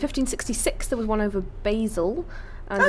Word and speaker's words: Basel: And I think it's pronounced Basel. – Basel. I mean, once again... – But I don't have my phone. – Once again, Natasha... Yeah, she's right, it is. Switzerland and it's Basel.